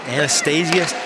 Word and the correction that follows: Anastasiadis: I can't say it right now. I need you, for that one. – Anastasiadis. – Thank you, Anastasiadis.